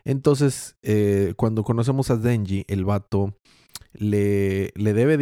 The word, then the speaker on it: Denji: Entonces, el protagonista, Denji, lo dije bien ahora sí, ¿verdad? Sí.